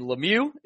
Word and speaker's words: Lemieux: But Kevin Hayes is traded to the Winnipeg Jets for a first and Lemieux. Is that how you pronounce his last name? Brendan Lemieux? – That is. It sure is.